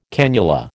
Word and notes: cannula: American English

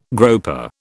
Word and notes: groper: British English

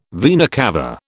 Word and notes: vena cava: British English

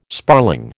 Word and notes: sparling: American English

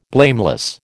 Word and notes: blameless: American English

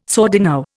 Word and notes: sordino: Indian

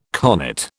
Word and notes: connate: British English

